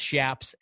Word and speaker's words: chaps: I've always pronounced Shaps as Shaps, I guess it comes from the word chaparral, the brush.